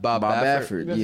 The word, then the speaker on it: Bob Baffert: Or Bob, how do you say Bob? – Bob Baffert.